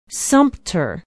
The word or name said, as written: sumpter